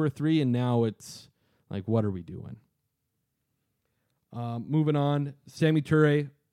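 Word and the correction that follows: Samori Toure: Sammy, I didn't pronounce that correctly. – No, Samari. – Samari Toure. There we go.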